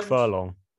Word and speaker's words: Tadhg Furlong: And just a general question: how do you say Furlong's name for Ireland? – Tadhg Furlong. – The Tadhg. Thank you.